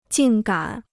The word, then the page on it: 竟敢: jìng gǎn